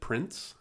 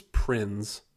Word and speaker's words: Prinze: Freddie Prinze Jr., which a lot of people, you know, always pronounce it Prince. I think it's Prinze. You have to pronounce the Z.